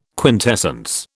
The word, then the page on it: quintessence: British English